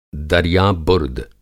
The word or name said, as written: दरिया बुर्द